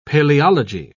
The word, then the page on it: paleology: British English